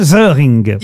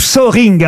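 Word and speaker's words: Söhring: C'est son vrai nom, Hans-Jürgen Zöring. – Il vit en France Ou Zöring, vous prononcez comme vous voulez. Ça s'écrit S-O-E-H-R-I-N-G.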